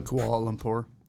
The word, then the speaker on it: Kuala Lumpur: At the very beginning, he moves from Nigeria to, I believe it's pronounced Kuala Lumpur. That is a Kuala Lumpur.